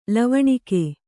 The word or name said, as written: ಲಾವಣಿಗೆ